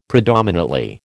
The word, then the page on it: predominately: American English